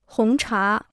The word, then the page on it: 红茶: hóng chá